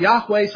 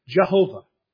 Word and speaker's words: Yahweh: which we pronounce Yahweh. Some people pronounce it Jehovah.